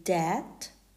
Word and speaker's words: debt: That's a B2 word level, and comb, C-O-M-B, which is an A2 level. It also becomes silent after the letter T, like in debt, D-E-B-T, or doubt, D-O-U-B-T. The letter I is silent in words like business, B-U-S-I-N-E-S-S, suit, S-U-I-T, and F-R-U-I-T, fruit.